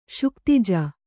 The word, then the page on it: ಶುಕ್ತಿಜ: śuktija